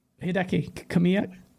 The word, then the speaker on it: Hideki Kamiya: Hideki Kamiya. – Hideki Kamiya, do you think that they saw like Phil and Satya going, came by some studios yes.